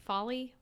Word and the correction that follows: Foley: Yes. – Follies. Yeah, he does folly work, and he composes music for stuff YouTubers. – Foley. – Yeah, Foley. He does Foley sounds.